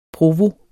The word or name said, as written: provo